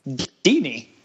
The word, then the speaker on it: Deeney: And his last name rhymes with Beanie. – Jared. – Yes, Jared. – Deeney.